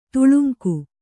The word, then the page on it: ತೇಲುಂಗ: tēluŋga